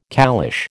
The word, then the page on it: caliche: American English